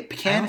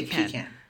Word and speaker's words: pecan: I say pecan because technically, if we're pronouncing it the way we were taught in kindergarten, where you pronounce every single letter, it's pecan. Because the E, there's no vowel to make it an E sound.